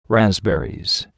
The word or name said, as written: raspberries